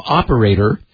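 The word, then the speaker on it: OPERA1ER: But a joint report published this week by security firm Group IB and Orange's CERT team, a French-speaking cyber group, tracked as, we'll pronounce them operator, although the T is a numeral one, so O-P-E-R-A, numeral one, E-R, also known as Common Raven or the Desktop Group.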